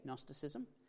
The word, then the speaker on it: Gnosticism: It's a fun word to say, Gnosticism. It's like a gnome. The G is silent. You don't actually pronounce it.